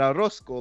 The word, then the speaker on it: Orozco: Mauricio Herrera, Antonio Orozco, fuck, I butchered it. – Orozco, my bad, Orozco, like un pollo.